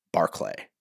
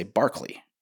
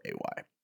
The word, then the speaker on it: Barclay: I was like, hey, can you give me the phonetic spellings of the names so that I, because, you know, like my last name's Barclay. And everybody wants to say Barkley, you know, or they want to spell it Barkley, but it's Barclay.